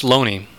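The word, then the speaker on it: Looney: Although it's pronounced Loney, tragically.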